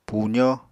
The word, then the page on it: puzó: apatani